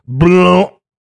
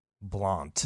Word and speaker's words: Blount: So Henry's first real mistress, like an outward mistress, was a woman named, and I love it, Elizabeth Blount. – Spell that. – B-L-O-U-N-T. – Blount. – Blount. – Yeah, he's like, pass the blount. – Blount. He's right. That's how that's said.